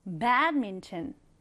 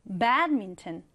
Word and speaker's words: badminton: 우리 배드민턴 읽을 때 배드민턴이라고 하지 말고 삼음절로 읽는 거예요. badminton. 한번 더. badminton. 네, 앞으로 이 배드민턴이라는 스포츠는 badminton이라고 읽어주시면 되겠습니다.